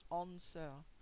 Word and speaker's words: answer: Vous avez l'apostrophe pour insister sur le A, son long, le W ne se prononce jamais dans un mot. Jamais. Answer.